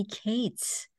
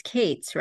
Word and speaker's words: Kates: It may interest you to know today I'm joined by filmmaker Cates. I hope I pronounced it right. It's Cates, right? – Kates, yes.